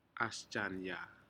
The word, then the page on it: asjanya: Aneityum